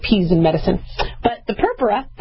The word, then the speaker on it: purpura: Okay, you know, we don't want anybody to spontaneously bleed, but we call those bruises in medical terminology purpura. Or purpura, right? I always put my accent on the wrong thing, but I called it purpura, and I think they corrected me as purpura.